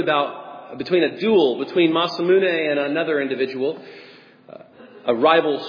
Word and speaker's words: Masamune: As far as what is noted, though, as the sharpest sword in history, comes by way of a Japanese swordsmith named Masamune. And I don't think I'm pronouncing that right. But you know what? Oh, is it really? I'm pronouncing it? Yes.